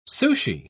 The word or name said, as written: sushi